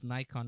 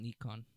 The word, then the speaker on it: Nikon: I just found out it's pronounced Nikon, not Nikon.